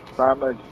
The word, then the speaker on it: Thomas: Randall Thomas. – Randall Thomas. Can you spell that for me? – Randall, R-A-N-D-A-L-L, Thomas, T-H-O-M-A-S.